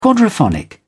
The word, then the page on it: quadraphonic: British English